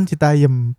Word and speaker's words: Citayam: tapi sebelumnya kita uh, kasih tahu dulu nih break ke pendengar celatu apa itu Citayam Fashion Citayam Citayam sih Citayam Citayam dah. Citayam iku begini nulis sih tapi secara pengucapan Citayem.